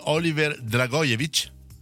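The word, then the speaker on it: Oliver Dragojević: è quella di Oliver Dragojevic, detto bene? – Sì, sì, giusto.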